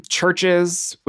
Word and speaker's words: CHVRCHES: Churches, which is spelled CHVRCHES, is a group from, I want to say Ireland.